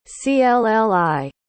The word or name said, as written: clli